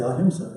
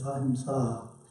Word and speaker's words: Ahimsa: Ahimsa is a challenging word to pronounce because it has a long A at the end. You want to say Ahimsa, right? That's the English way of saying it, but it's Ahimsa. That A at the end is long.